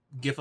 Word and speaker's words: GIF: So I take a GIF of a movie. I say hard G, deal.